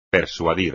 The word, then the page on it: persuadir: espagnol